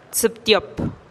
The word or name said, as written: tsïptiop